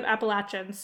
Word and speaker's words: Appalachia: but I've always been taught that it's pronounced like "I'm throwing an apple at you," as a fairly Appalachia. Appalachian adjacent person. it's Appalachia.